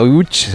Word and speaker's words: Łódź: Aunque se pronuncia Łódź, se escribe Lodz, por si alguien lo busca Lodz, en Google.